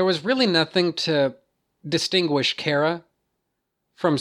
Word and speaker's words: Kara: I mean, first off, Supergirl, which is to say Kara, and that's her fucking name, by the way. I'm not going to follow the stupid-ass TV show pronunciation of her name. Her name is Kara.